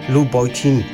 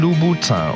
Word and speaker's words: Louboutin: Wie spricht man das immer aus? – Louboutin. – Nein, Louboutin.